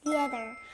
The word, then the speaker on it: theater: Theater. – That's right. 극장, theater. 여기 앞에 th 소리 들렸나요? 그럼 극장, theater 다시 발음해 볼게요.